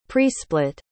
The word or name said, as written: presplit